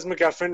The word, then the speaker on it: MacGuffin: It was named because MacGuffin rhymes with muffin, and it's when you steal a muffin from someone named Charles, the Charles MacGuffin.